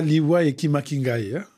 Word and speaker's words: Liwa Ekima Kingai: Est-ce que je prononce bien ? – Ouais, Lingala Liwa Ekima Kingai.